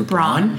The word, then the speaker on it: Braun: Scooter Braun.